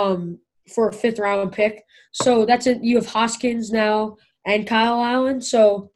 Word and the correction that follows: Haskins: Haskins. – I don't – Dwayne Hoskins, the Ohio State. – Dwayne Haskins.